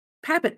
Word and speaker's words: Papin: Papin? – Papin. That's what I thought. The Papin sisters. Okay. – I know that's not the French way to say it.